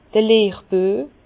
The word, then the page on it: d'r Lehrbüe: Bas-Rhin; Herrlisheim